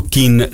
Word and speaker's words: Kean: Siccome mi sono informato da amici inglesi come si pronuncia Kean, tutti mi hanno detto Keane.